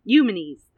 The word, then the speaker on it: Eumenes: And I had the best time trying to learn how to pronounce that name, by the way, because I was like, is that name Eumenes? Is his name Eumenes? Like, Eumenes. Eumenes. That's it.